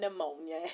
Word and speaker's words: pneumonia: At least there will be plenty implied if you got pneumonia and die. Question. Why do they spell ammonia pneumonia? Like, see, the poor P, it's there for no reason.